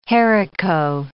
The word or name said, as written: haricot